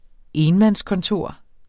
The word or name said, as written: enmandskontor